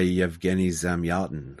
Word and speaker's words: Yevgeny Zamyatin: We by Yevgeny Zamyatin.